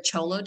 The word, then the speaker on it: Xolo: And then there's also the Cholo dog. So, it's spelled XOLO. And so the X is pronounced like a ch sound, like CH.